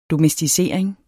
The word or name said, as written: domesticering